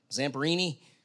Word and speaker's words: Louis Zamperini: Louis Zamperini. Did I say that right? Zamperini? That was a good accent. Close enough.